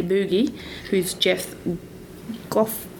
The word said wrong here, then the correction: Gothelf: I'm going to say it wrong. – Gothelf. – Thank you.